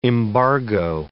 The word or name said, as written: embargo